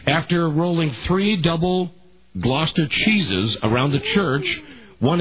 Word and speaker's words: Gloucester: Yep, Gloucester.